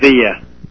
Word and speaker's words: Padilla: Is that how you pronounce it? Padilla.